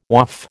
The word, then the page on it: Waaf: American English